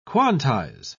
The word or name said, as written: quantize